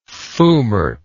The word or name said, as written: foumart